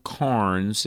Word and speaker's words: Cairns: Christianity Through the Centuries by a guy named Earl Carnes, and it's spelled like C-A-I-R-N-S, Christianity Through the Centuries.